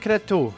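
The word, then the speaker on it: Cretu: Dzimšanas diena tu izpētīji, kas tur ir ar viņu, kā viņš ir jāizrunā. – Nu, viņš ir Mihails. Viņš ir vispār Rumānijas izcelsmes. Rumāņu izcelsmes. Mihails Kretu.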